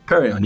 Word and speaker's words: Perion: Perion, yeah.